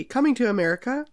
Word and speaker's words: Coming to America: Sorry, that's a soft T. Coming to America.